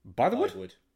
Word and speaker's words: Bythewood: Um, Bythewood. I, am I am I am saying that right. I am not like just fucking yeah, imagining.